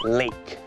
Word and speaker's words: lake: อ๋อคำว่าบึงน้ำคือ lake ไม่ได้ออกเสียงว่า leg แปลว่าขาแต่คำนี้มันออกเสียงว่า